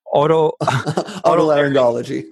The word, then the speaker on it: Otolaryngology: Otolaryngology. – Otolaryngology.